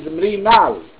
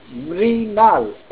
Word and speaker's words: Mrinal: And my name is Mrinal, Mike, Romeo, India, November, Alpha, Lima. Mrinal, M-R-I-N-A-L, Mrinal.